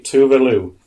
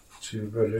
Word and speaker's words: Tove Lo: So, Swedish pop singer, and I'll pronounce it properly, Tove Lo. – Tove Lo. – But it's pronounced... – How do you spell it? – T-O-V-E-L-O.